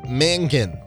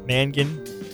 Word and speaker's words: Mangin: Week 9, August Mangin. – Mangin? – Could be Mangin. – Mangin? Oh, so like M-A-N-G-I-N. Mangan.